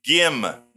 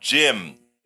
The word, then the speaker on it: Gim: Her name is Gim, not Jim.